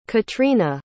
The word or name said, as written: katryna